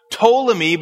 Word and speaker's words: Ptolemy: And then we're going to go from Ptolemy, which I think is probably pronounced Ptolemy, but it's spelled with the P in front, Ptolemy 1 to 5 versus Seleucus 1 to 4 and Antiochus 1 to 3.